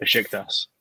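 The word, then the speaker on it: Beşiktaş: I mean, just like when Dele Alli went to Besiktas. I don't know how to say it. – Besiktas.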